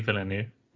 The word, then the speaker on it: Villeneuve: Yeah, Denis Villeneuve.